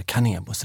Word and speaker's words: Kanebo: Så redan där har namnet funnits med, men sedan 2014 faktiskt, så är Sensai Cosmetics en egen del under Canebos vingar. Mm. Så ligger det till. – Perfekt. – Men Canebo uttalar vi i alla fall.